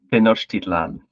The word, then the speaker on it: Tenochtitlan: One of them is I'm going to get the pronunciation wrong, but it's Mexico Tenochtitlan, which is the... – Can I interject with the pronunciation? – No. – Okay. Apology accepted. – Go ahead. – Tenochtitlan. – Tenochtitlan. Okay. Close enough.